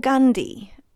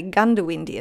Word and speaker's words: Goondiwindi: It's spelt Goondiwindi, but it gets called Gundi, and I'm confused by that. And I have heard of people say Goondiwindi as well, but there's definitely two O's.